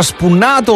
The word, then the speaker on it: spunnato: Spunnato, ma si pronuncia spunnato o spannato? – No, spunnato come te lo sto dicendo.